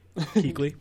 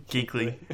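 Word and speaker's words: Kuechly: Keekly. – Keekly. Keekly.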